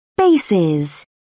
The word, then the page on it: bases: British English